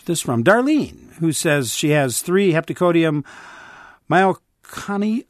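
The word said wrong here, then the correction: miconioides: Myconioides. I knew I'd mispronounced that. Myconioides.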